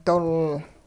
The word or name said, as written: dol